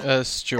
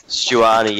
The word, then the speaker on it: Stuani: Is it pronounced Stanuai? Is that Stuani, how we... I think, yeah. – Stuani, yeah, sorry.